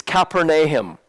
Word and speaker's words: Capernaum: In Israel today, they call it Capernaum. So when I was over there pronouncing Capernaum, nobody knew what I was talking about. It's Capernaum.